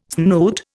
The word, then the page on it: snoot: Indian